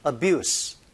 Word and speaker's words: abuse: abuse, 명사니까 z가 아니고 s 발음인데요.